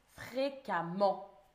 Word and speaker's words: fréquemment: Ça se dit fréca, mais ça s'écrit avec un E. Fréquemment.